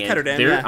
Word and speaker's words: Ketterdam: Oh, it's Ketterdam, I said it right.